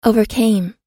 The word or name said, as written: overcame